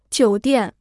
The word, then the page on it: 酒店: jiǔ diàn